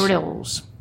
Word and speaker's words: Grylls: Grylls. It's G, like it would be G-R- Short I-L-Z, Grylls.